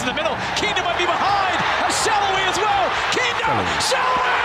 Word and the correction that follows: Salloy: Somebody's got to tell this kid how to pronounce his name. Saloy. S-A-L-L-O-Y. Saloy.